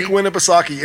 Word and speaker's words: Winnipesaukee: Lake Winnipesaukee.